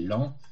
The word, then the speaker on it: Laon: Puis il y a le Graduale triplex en 1979, avec des neumes sangalliens, mais également des neumes de Laon, de Laon, on doit prononcer Laon en Picardie, hein, de l'abbaye de Laon, la bibliothèque de Laon.